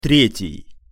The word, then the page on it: tretij: Russian